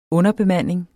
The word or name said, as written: underbemanding